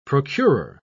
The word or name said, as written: procurer